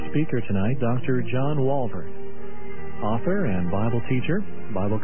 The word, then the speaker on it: Walvoord: And again, the speaker, Dr. John Walvoord. That's spelled W-A-L-V-O-O-R-D. Walvoord.